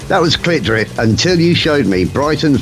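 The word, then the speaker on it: CLT DRP: They are spelt in capital letters CLT space DRP, and very helpfully on their Bandcamp page they put pronounced clit drip.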